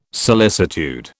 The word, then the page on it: solicitude: British English